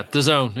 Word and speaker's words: DAZN: Yeah, DAZN.